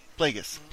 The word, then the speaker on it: Plagueis: Plagueis.